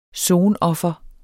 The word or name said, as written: SONOFRENES